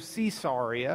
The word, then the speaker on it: Caesarea: A good Roman Latin would have pronounced it Caesarea. Okay? It's after Caesar. So if you want to do Caesarea, or it doesn't matter.